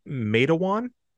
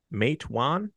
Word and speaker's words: Matewan: It's called it's Matewan, Matewan, M-A-T-E-W-A-N. – Okay. Matewan. – Matewan, Matewan. So it'll be interchangeably mispronounced, and I apologize.